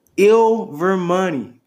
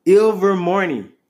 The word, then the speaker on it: Ilvermorny: Ilvermorny. Ilvermorny. No, that's wrong. Ilvermorny. There we go. I said it right that time.